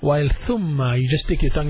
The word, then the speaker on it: ثم: ثاء, ضم on top of the ثاء, so pronounce it ثو, then ميم. Then أشد تشديد on top of the ميم, so he says ثم. تشديد looks like a small W on top of the letter ميم, so we just pronounce it ثم.